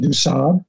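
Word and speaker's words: Du Sable: I've learned the French pronunciation, Du-Sable.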